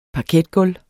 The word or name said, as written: parketgulv